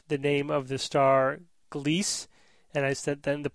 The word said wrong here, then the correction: Gliese: But I was informed by a listener that it's actually a German word, and it's supposed to be pronounced Gliese, as two syllables, not one.